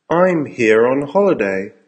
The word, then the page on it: I'm here on holiday: American English